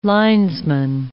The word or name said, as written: linesman